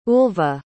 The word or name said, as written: ulva